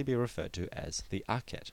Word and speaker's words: Akhet: This glyph, which shows the sun setting between two mounds, is pronounced Akhet and is the logogram for the word horizon.